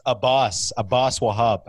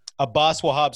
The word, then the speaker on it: Abbas Wahab: Hey, but so you know my name, a lot of people it's pronounced Abbas Wahab. Abbas, obviously, you know it's Abbas. But Abbas Wahab.